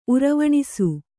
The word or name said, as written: ಉರವಣಿಸು